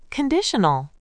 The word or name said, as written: conditional